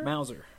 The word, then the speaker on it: Mauser: Mauser? – Mauser, yeah.